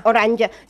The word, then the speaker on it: Oranje: Oranje. So say, but it's a German. It's German. – Yeah. – So you actually, although it's spelled with a J, you spell it with a Y.